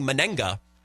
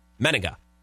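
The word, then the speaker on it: Manaea: I was saying Menega up until yesterday when our friend and frequent contributor to the show, Zach Heilprin, in his Sports Zone update, properly pronounced his name, Sean Menega.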